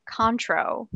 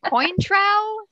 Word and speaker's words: Cointreau: Anyway, this drink contains 1.5 ounces of bourbon, 0.75 ounces of, it is pronounced Contro. – Okay. – I looked it up. – Okay. – After last week, I was like, Cointreau? No, that's not.